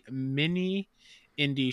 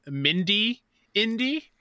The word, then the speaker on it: Mini Indie: instead of saying mini indie, I was saying Mindy indie. So I'm really focusing on saying it correctly.